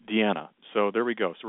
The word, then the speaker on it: Dina: And I just kept, it's spelled Diana, but I know that it's Deanna, like my friend Deanna Ballard out of Chicago, Illinois, who's listening tonight.